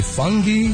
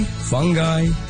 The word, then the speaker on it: fungi: So how do you pronounce the word F-U-N-G-I? It's a very fun word. It starts with fun, but then how do you end it? Do you say fungi, fungi, fungi, fungi? Because there are four acceptable pronunciations.